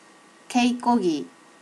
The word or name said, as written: keikogi